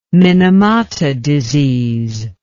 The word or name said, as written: minamata disease